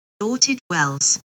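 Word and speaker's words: Llanwrtyd Wells: Lanerted Wells. – Lanerted? – Yeah, that is the UK pronunciation.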